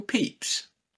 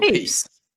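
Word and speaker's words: Pepys: Isn't it pronounced Samuel Peeps? – Is it? Is that me just not pronounce? You know when you've never heard a word said out loud? I'm doing that right now. – Yeah, I think it's Samuel Peeps.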